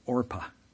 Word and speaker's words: Orpah: So now if you're confused, because her name's not Orpah, but Oprah, that's because Oprah's name is not Oprah. It is Orpah. But because people routinely mispronounced her name, she just decided, and her family decided, they just ignore it and let it be.